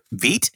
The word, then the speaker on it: Vite: What did you call it? Vite? – Vite. – Yeah, it's a French word meaning fast. – It's a how do you spell it? – V-I-T-E. – Oh, see, I thought you said V-E-E-T, like the stuff that removes hair off your bikini line.